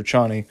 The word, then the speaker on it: Chani: Zendaya plays Shani. Is it, or Chani? Let me make sure. I may be mispronouncing that slightly, but yeah, Chani, yeah.